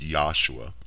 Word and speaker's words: Joshua: The story is found in Joshua, pronounced Yahshua, chapter 2.